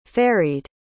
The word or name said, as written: ferried